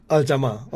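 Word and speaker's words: Al Jama-ah: How is it pronounced? Al-Jama'ah? – I say Al-Jama'ah. – Al-Jama'ah, okay. So it's like French, the ah is silent at the end.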